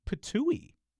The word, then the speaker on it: Ptuj: It's spelled P-T-U-J. – Yeah, and I don't remember if we've talked about this. I don't think so, but I came across this at some point, and I think it turned out that it actually is pronounced the way I wanted it to be, which is unusual. It's like patooey.